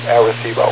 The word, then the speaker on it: Arecibo: By the way, in that country, they do pronounce it Arecibo.